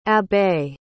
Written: abbé

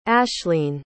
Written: ashleen

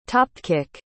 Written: topkick

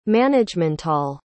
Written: managemental

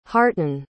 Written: harten

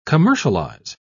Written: commercialize

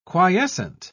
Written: quiescent

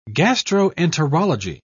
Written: gastroenterology

